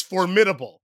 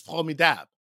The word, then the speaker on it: formidable: But when you read it in English, it's formidable. – Right, right. – But the way it's pronounced in French is formidable.